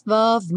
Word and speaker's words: of: Love of my life. Aqui, revisando of, no caso com som de V, esse F com som de V, e o love, como é, não é pronunciado, já vai ligar direto no O, né?